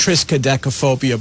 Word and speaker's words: triskaidekaphobia: And it's pronounced triskaidekaphobia, by the way.